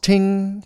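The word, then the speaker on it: ting (听): In Chinese, it's pronounced ting, first tone, ting.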